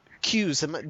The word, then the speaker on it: Qs: Qs. Did you say I'm pronouncing that right? – Yeah. – Okay. – Qs, as in news, but with a Q.